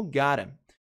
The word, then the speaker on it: got to: Mais uma vez, got to, que soa como gotta.